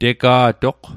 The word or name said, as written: tega'toq